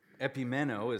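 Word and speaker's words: Epimeno (ἐπιμένω): Epimeno is how you say it in the Greek if you care.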